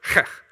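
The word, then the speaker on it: he: And so we are on the fifth one, and this is the word, the letter, heh. Now, I'm not just clearing my throat there. That's how you pronounce it in Hebrew, to the best of my ability.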